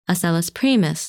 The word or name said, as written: Asellus Primus